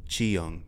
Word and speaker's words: Cheung: Are you sure you're pronouncing that correct? – Yeah, yeah. It's spelled like this. C-H-E-U-N-G, Chiang.